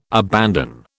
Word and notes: abandon: British English

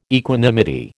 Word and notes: equanimity: American English

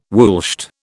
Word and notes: woolshed: British English

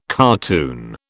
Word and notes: cartoon: British English

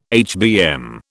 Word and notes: HBM: British English

